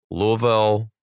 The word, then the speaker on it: Louisville: Then the 16th and 17th, two shows each night in Louisville, Kentucky and the Louisville people are coming for me because apparently I keep saying it wrong, but Louisville, there we go.